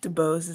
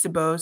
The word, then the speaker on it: DeBose: DeBose is how you pronounce it. Everyone always gets caught up on it and they're like, should I say it? Should I? You know, it's been my entire life, but it's DeBose.